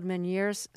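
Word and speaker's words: Ménière's: Now, after you became the first American in space, you got something called Meniere's syndrome.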